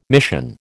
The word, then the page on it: mission: American English